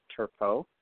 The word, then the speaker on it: Turpeau: Yes, I do have a YouTube channel under my name, Aaron Turpeau, and that's spelled Aaron, A-A-R-O-N. Last name is Turpeau, and that's spelled T-U-R-P-E-A-U.